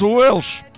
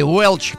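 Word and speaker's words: Welch: И сегодня свой день рождения, 39 лет, отмечает Флоренс Уэлш. Или Уэлч, смотря на какой манер произносить.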